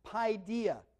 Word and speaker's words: paideia: Okay, the Greek word is paideia. Be spelled like P-A-I-D-E-I-A, okay?